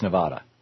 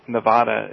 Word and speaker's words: Nevada: And Nevada is closer to the Spanish pronunciation.